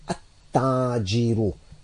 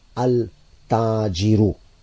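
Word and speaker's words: at-tajiru (التاجر): I cannot say al-tajiru. I have to say at-tajiru, the merchant. It will be absolutely wrong to say al-tajiru.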